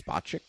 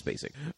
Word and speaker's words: Spacek: which apparently is supposed to be pronounced Spacek, which nobody did, so it's Sissy Spacek,